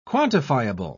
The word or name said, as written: quantifiable